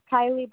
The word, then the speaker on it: Kylie: Kylie Burnett. – Can you spell that for me? – K-Y-L-I-E. B-U-R-N-E-T-T.